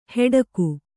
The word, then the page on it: ಹೆಡಕು: heḍaku